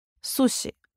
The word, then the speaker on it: суши: Необходимо помнить, если после знака «с» стоит мягкий гласный «и», «я», «ю», «ё», то звук более мягкий, чем русский «с». «Суши» — это «суши».